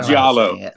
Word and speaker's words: Giallo: Am I saying that right? Gallo? – Giallo, I think that's how Giallo, thank you.